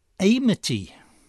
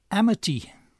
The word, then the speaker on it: Amity: On the rare occasions during those years that her name came up, I pronounced the initial A of Amity as a diphthong, the way we pronounce it in amiable or atheists. I said Amity. Wednesday evening I learned, hearing her being introduced at the dinner, that her name is actually pronounced with a short initial A, as in amble or amnesia.